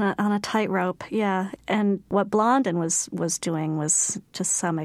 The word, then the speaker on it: Blondin: There was a guy named Blondin.